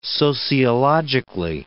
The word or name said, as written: sociologically